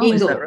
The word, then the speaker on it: Ingall: Ingall.